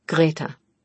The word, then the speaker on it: Greta: Greta rhymes with Beta.